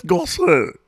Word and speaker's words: Gosse: G-O-S-S-E. Gossy? – Sure. – Gossé? – Gossé? – Say it like Julia. – What's his first name? – Ken. – Kenneth Gossé. – Perfect. Dough.